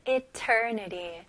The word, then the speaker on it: eternity: 네, 스펠링은 E지만 I로 생각하시면 좋을 것 같아요. eternity. 네리. 마지막 T는 아주 가벼운 D로 발음하시면 훨씬 더 쉽게 발음할 수 있어요.